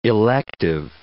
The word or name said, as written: elective